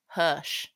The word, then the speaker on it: Hirsch: Is it Hirsch? Hirsch. Mm-hmm. Hirsch. This is what I do on every episode. And Emile Hirsch.